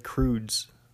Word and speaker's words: Krooze: And he plays Tom Cruise, but spelled like Croods, K-R-O-O-Z-E, like snooze.